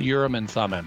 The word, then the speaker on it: Urim and Thummim: The one on the Uma and the Thurman. How do you say it? – The Urim and Thummim.